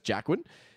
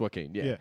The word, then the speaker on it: Joaquin: Like, if before now, everyone referred to him as Jackwin, and now everyone is Joaquin? – Yeah. I've always called him Joaquin. – Yeah, it's Joaquin,